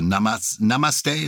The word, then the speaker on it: namaste: I am now got into the habit of the namaste.